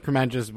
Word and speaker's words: Kremenjas: Sosa Kremenjas with us.